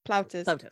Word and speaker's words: Plautus: I can't Plautus. – Pronounce that. – Plautus.